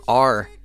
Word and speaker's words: R.LUM.R: R.LUM.R. is spelled R.LUM.R, pronounced R.LUM.R.